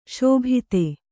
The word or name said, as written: ಶಾಬ್ದ